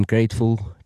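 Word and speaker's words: Gr8ful: So I went with the name Grateful. It's spelled G-R and then the number 8, F-U-L.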